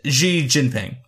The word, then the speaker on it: Xi Jinping: It's Xi Jinping is his name.